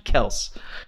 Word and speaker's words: Kelce: He's like, yeah, it's actually Kels.